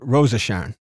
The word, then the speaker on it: Rose of Sharon: But Tom pronounces it Rosa Sharon. – Rosa Sharon, right.